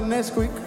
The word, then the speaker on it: NSQK: Para la gente que quizás no sabe quién soy, mi nombre es NSQK, pronunciado Nesquik o Roy, como el chocolate.